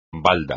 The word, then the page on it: balda: espagnol